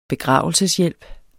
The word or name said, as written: begravelseshjælp